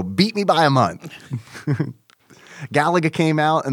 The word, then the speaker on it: Galaga: Galaga, or as it's known in Japan, Garaga.